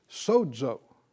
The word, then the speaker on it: sozo: Now this word translated from the Greek to the English here, whole, is the word sozo. S-O-Z-O, you know, in the dictionary, I think they pronounce it with kind of a D sound, sozo.